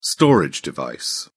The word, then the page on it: storage device: British English